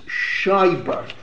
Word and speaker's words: Scheiber: I mentioned that the head of the Neolog Rabbinic Seminary in Hungary was Schreiber. It's Schreiber. The name is spelled S-C-H-E-I-B-E-R.